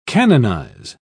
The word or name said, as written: canonize